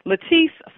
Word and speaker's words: Latisse: Latisse for your eyelashes.